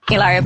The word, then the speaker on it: Hilaria: Hilaria Baldwin.